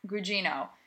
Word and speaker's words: Gugino: Gugino.